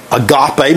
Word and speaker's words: agape: And then, of course, the highest of those is agape. Most of us pronounce it that way. Agapao, if you want a more of a Greek pronunciation.